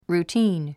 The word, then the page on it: routine: ruːtíːn; ルーティーン